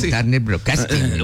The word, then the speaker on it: Turner Broadcasting: En 1992, la empresa Turner Broadcasting, ¿lo dije bien? – Turner Broadcasting.